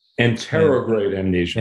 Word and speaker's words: anterograde: And, you know, my understanding is that the amnesia experienced by the lead character in that film, Guy Pearce, is, I may butcher the pronunciation of this, anti-retrograde amnesia, anti-retrograde versus retrograde amnesia. – Anterograde amnesia. – Anterograde, yes.